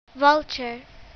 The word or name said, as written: vulture